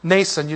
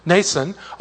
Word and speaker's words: Nason: Also, some of the disciples from Caesarea went with us and brought with them a certain Nason, you don't pronounce the M in this name, Nason of Cyprus, an early disciple with whom we were to lodge.